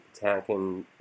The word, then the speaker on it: Patinkin: Like, I think while Sean is great in it, I think Mandy Patinkin, or how do you say his name?